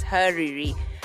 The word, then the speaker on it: Hariri: I need to get this right, it's Hariri. Tell us a little bit about Hariri and also why it's Hariri, so we all know how to pronounce it. – Okay, hi there, guys. So Hariri, spelled H-A-R-I-R-I, means salt in Swahili.